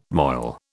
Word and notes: moil: British English

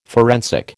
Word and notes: forensic: American English

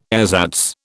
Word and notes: ersatz: British English

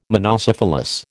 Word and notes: monocephalous: American English

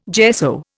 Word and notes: gesso: Indian

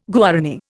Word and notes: Guarani: Indian

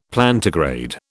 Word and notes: Plantigrade: British English

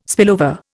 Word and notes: spillover: Indian

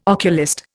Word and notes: oculist: Indian

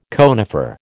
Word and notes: conifer: American English

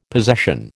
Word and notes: possession: American English

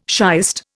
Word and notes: shiest: Indian

